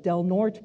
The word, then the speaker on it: Del Norte: Of course, Fresno, the Imperial Valley, San Diego, but also, for example, earlier this year, I went to Del Norte County. The E is silent, I was told, up there.